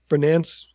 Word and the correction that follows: Berlanti: Bernance, the producer of these Berlanti. – Shows. Berlanti.